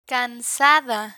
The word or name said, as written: cansada